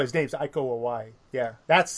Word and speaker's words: Iko Uwais: And the guy from the Raid movies, the actor from the Raid movies, I believe his name is pronounced Iko Uwais.